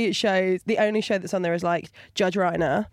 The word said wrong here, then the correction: Rinder: Oh, Judge Rinder. – Rinder, Reiner, Rimmer, whatever. – Thank you. Made it rhyme with grinder there.